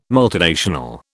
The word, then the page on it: multinational: British English